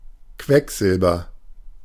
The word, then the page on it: Quecksilber: /ˈkvɛkˌzɪlbɐ/